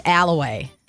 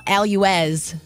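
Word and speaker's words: Allouez: Did not know when I first moved up to Green Bay, but I learned that one real quick. It's Alloway. – Wow. – It's not Alouez.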